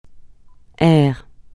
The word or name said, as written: aire